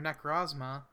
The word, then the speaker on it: Necrozma: This is an exclusive move to Necrozuma, or Necrozma. I don't know how it's pronounced. – They didn't put the U there.